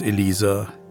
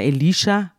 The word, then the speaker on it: Elisha: Ich sage immer Elisha, hm. weil in meiner Bibel steht Elisha und in der Übersetzung von Luther steht Elisa. Hm. Also das wird unterschiedlich ausgesprochen.